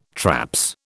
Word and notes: traps: British English

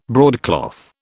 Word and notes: broadcloth: British English

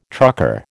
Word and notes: trucker: American English

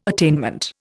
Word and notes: attainment: Indian

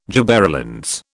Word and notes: gibberellins: British English

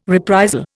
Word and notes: reprisal: Indian

